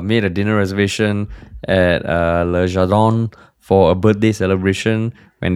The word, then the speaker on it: Le Jardin: Le Jardin, yeah. – Oh, that's how you pronounce it in French, yeah? – Yeah, like in French. Yeah. – Le Jardin.